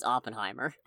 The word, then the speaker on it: Oppenheimer: I'm pretty sure it's Oppenheimer.